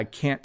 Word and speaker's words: can't: So again, I know other English teachers on YouTube have explained this, that we don't really pronounce the T. We do say it a little bit though. So listen again. Yes, I can take you to the airport. No, I can't take you to the airport.